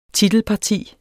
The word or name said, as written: titelparti